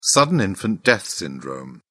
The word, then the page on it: sudden infant death syndrome: British English